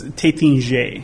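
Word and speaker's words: Taittinger: But his pronunciation was Tattinger. – Tattinger. If I'm in France, I bet it's Tattinger if I'm in France. Tattinger. Because you're reading it, it's three T's.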